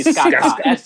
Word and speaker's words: Skaka: S-K-A what? – S-K-A. Same thing twice. – Oh, Skaska. – Oh, no, actually, Skaka. Sorry, no second S. Let me spell it the right way. S-K-A-K-A.